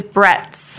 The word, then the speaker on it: Bretz: It is Bretz.